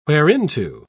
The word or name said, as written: whereinto